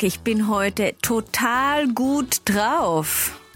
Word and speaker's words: drauf: Ja, G-U-T, yeah. Und dann drauf, D-R-A-U-F.